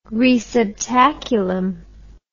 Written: receptaculum

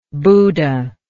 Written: buda

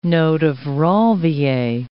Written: node of Ranvier

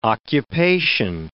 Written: occupation

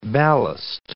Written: ballast

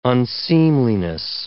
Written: unseemliness

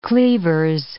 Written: cleavers

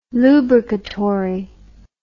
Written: lubricatory

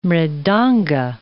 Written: mridanga

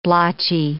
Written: blotchy

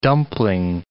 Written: dumpling

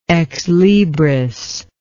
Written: ex libris

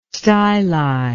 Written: styli

